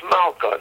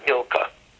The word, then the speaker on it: Milka: We pronounce Malka, not Milka.